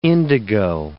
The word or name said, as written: indigo